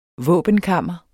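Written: VÅBENKAMRE

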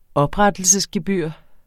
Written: oprettelsesgebyr

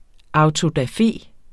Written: AUTODAFE

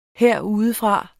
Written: herudefra

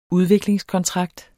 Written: udviklingskontrakt

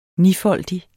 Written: nifoldig

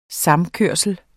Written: vinterfoder

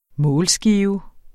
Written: målskive

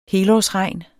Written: helårsregn

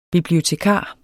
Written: bibliotekar

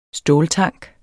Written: ståltank

